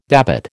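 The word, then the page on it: début: American English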